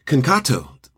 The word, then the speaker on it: Concato: Today, we were talking about caregiving with Jane and Joe Concato.